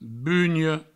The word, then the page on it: bunha: bugno